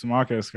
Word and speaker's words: Marques: Today we have Mark. Am I saying that right? – Marques, right.